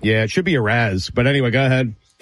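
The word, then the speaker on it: Arráez: Arias. – Luis Arias. Whatever it is. Yeah. I mean, Jesus Christ. But Luis, how do you say it again? – Arise.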